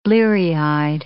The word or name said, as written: bleary-eyed